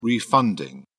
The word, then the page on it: refunding: British English